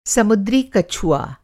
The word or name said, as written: समुद्री कछुआ